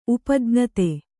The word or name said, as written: ಉಪಜ್ಞತೆ